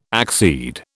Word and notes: accede: British English

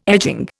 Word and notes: edging: Indian

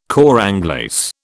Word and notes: cor anglais: British English

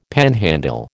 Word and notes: panhandle: American English

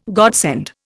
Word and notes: godsend: Indian

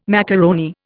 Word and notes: macaroni: Indian

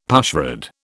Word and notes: pushrod: British English

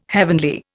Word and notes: heavenly: Indian

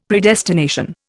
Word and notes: Predestination: Indian

